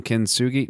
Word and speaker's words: kintsugi: So I can hear that Asian influence in the Walking the Camino book, specifically with that concept of, I'm going to say it wrong, kintsugi? No, that's not how you say it.